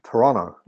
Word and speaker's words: Toronto: Or as the locals say, Toronto. You drop the T's if you're really cool.